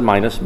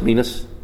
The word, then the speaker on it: minas: Some pronounce it minus, minas, minus, same thing.